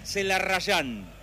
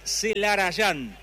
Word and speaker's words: Celarayán: que para todo el mundo es Lucas Celarrayán, pero en realidad, tanto en su documento, que es lo más importante, como en su camiseta, dice Celarayán, porque se escribe y se dice así.